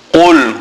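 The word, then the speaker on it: Qul (قُلْ): Now note the qaf. Qaf must be pronounced from the furthest point in the mouth, right in the back of the mouth. Qul huwallah.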